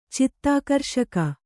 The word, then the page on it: ಚಿತ್ತಾಕರ್ಷಕ: cittākarṣaka